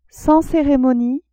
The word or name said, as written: sans ceremonie